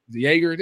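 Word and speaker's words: Joerger: How does new coach David, I don't even pronounce his name. I only read it. David Yorger. – Jaeger. – There you go.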